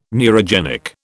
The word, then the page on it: neurogenic: British English